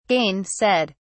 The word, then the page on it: gainsaid: British English